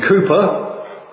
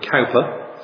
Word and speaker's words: Cowper: And if you know your hymn writers, you will know that William Cooper, some people pronounce it Cowper, C-O-W-P-E-R, he was a depressive for most of his life.